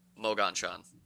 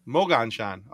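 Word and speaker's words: Moganshan: Is so how is it pronounced? – No, that's just about right. It's like Mogonshan. – Mogonshan.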